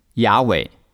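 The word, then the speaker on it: Yahweh: 今天的圣经学者公认 YHWH 的正确读音是雅伟。生命记 6:4 希伯来原文里面有两次提到了神的名字，中文的译本是完全看不出来的。这节经文的直接翻译是：以色列当听，雅伟你的神是独一的雅伟。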